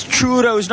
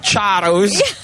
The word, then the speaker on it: churros: Saeed over here reminding us that it's churros, not charros.